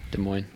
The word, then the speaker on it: Des Moines: That's how you say it, right? Des Moines? – Des Moines, Iowa.